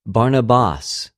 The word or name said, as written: Βαρναβας